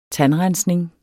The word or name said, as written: tandrensning